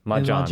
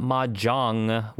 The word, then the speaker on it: Majohn: It's not mahjong with the ending in a g, which is the uh, f, uh, eh, which is the game that is played. It's Majohn, like the common English name John, Majohn.